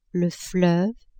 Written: fleuve

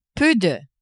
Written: peu de

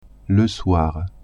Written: soir

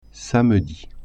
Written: samedi

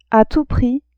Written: a tout prix

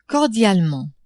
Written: cordialement

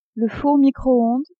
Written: four micro-onde

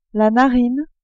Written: narine

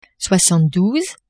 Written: soixante-douze

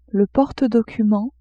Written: porte-documents

